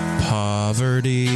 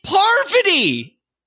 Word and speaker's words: Parvati: Please stop pronouncing it. Poverty. – It's crazy. She's like she is one of the Mount Rushmore Survivor players, and no one can say her name right? Get out of here! – That was from the supergroup Bitter Journey. – What the crab cakes? It's Parvati!